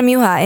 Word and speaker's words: Muha: And you say it Muha. – Yeah. – I said it wrong. – No. – Muha. – No, it's either. Okay. It's all right. – Yeah. We have both. In my family, they say both.